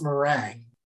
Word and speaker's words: meringue: It's a frozen shell of merengue filled with hot liquor. – I think it's pronounced meringue. – Oh, is it? Whatever it is. – I think meringue is like a dance or something.